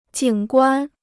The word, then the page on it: 景观: jǐng guān